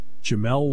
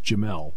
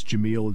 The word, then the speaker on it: Jamel: We had Anthony Donko, Kozai Izzard, Jamel Lyons, and that is how you pronounce it, by the way. It is Jamel. It looks like it's Jamil. It's Jamel.